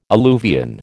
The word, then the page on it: alluvion: American English